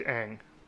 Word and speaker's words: Aang: I picked Aang. – Oh. – He said it right.